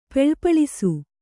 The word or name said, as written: ಪೆಳ್ಪಳಿಸು